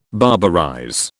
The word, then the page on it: barbarize: British English